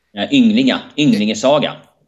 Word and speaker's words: Ynglinga saga: In the Ynglinga saga, did I pronounce that correct? Ynglinga? – Ynglinga saga.